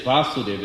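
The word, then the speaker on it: Vāsudev: Krishna is Vasudev with a long A. His father is Vasudev, short A.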